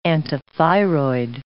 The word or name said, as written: antithyroid